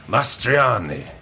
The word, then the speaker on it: Mastroianni: Say it in Italian, then in your Italian voice, and everyone will believe you're saying it right. Mastroianni.